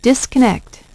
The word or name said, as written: disconnect